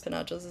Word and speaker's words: Pinachos: But my handle is CJ Pinachos. Pinachos is my last name. So, just think PI Nachos.